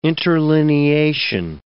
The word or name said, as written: interlineation